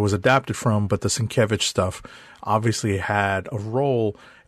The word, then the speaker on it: Sienkiewicz: This mid-credit scene is at, apparently, Sienkiewicz Psychiatric Hospital. – Sienkiewicz. – Yes. – Sienkiewicz.